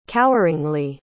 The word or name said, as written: coweringly